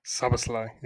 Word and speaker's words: Szoboszlai: Dominic Szoboszlai. – Szoboszlai, yeah. – It's that easy. – You got it pretty right. Yeah, it's not really that hard. It's the way it looks that's intimidating.